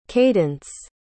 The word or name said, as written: kaydence